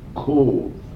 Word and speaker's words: Cobh: Left Ireland from the town that they used to sail from in those days, Cove, in County Cork. It's spelled C-O-B-H.